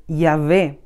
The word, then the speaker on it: Yahvé: Este tetragamatón se llama a las cuatro letras ya unidas y en su pronunciación es Yahvé.